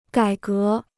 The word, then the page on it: 改革: gǎi gé